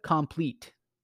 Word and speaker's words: Compleat: Kalja Compleat. And it's spelled kind of differently for complete. It's C O M P L E A T.